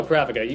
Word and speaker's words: Pravica: No, Pravica.